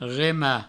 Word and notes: remar: rema

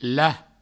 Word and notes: lach: la; Provençal

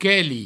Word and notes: queli: provençal

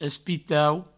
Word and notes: espitau: provençal

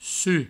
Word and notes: suc: provençal; su

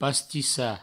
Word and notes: pastissar: pastissa

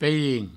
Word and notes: pairin: peirin

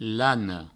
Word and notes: lana: lano; Provençal

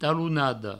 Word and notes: talonada: provençal; talounado

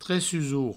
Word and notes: tressusor: tressusour

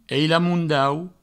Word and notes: ailamondaut: eilamoundaut

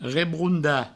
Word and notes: rebrondar: Provençal; rebrounda

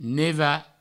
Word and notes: nevar: neva

